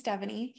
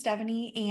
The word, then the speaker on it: Devoney: So my name is Devoney. Yes, I pronounce just that's the easiest way to explain how to pronounce it, like Stephanie. So my name is Devoney and I live in San Diego.